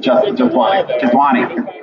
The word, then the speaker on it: Jethwani: Am I going to say this right? Jethwanai? – Jethwani.